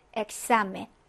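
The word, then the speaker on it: examen: 그래서 첫 번째로는 이제 크스 발음이라고 했습니다. 그래서 받침 기역, 자음 시옷 발음이라고 해서, 자, 가장 늘 예로 나오는 부분이에요. 자, 요거는 엑사멘.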